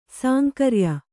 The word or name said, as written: ಸಾಂಕರ್ಯ